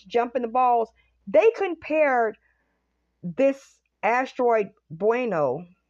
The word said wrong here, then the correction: Bennu: to being like that. Bennu. His name is Bennu. I always say his name wrong.